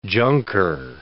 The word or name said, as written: junker